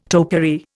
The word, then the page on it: topiary: Indian